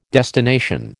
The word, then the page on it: destination: American English